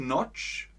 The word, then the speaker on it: Notch: Der schwedische Programmierer Markus Torch, Scotch, Notch, Persson hat Minecraft erfunden.